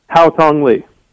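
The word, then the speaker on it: Haotong Li: Is it Wao Tung Lee? Is that how you pronounce it? What is it? – Hao Tong Lee.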